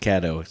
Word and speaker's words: Caddo: The 5, Caddo River, Dwayne Lucas trainer. – Caddo River or Cato River? – I believe it's pronounced Caddo, C-A-D-D-O.